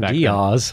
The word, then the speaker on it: Diaz: Yeah, don't forget Cameron Diaz. – Cameron Diaz, her single was blaring. – You say her name very strange. – How are you supposed to say it? – Cameron Diaz. Right? – Diaz.